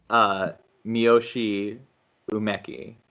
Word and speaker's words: Miyoshi Umeki: Miyoshi Umeki.